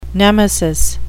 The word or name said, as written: nemesis